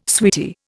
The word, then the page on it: sweetie: Indian